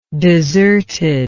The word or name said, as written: deserted